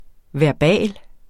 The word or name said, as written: VERBALT